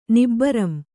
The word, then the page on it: ನಿಬ್ಬರಂ: Kannada